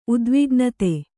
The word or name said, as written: ಉದ್ವಿಗ್ನತೆ